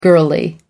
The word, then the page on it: girlie: American English